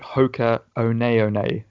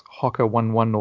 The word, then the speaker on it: Hoka One One: obviously people have been hearing us pronounce it Hoka One One. It's not Hoka 11 or Hoka 11, which you hear often.